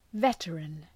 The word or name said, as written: veteran